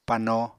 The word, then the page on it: pano: Apatani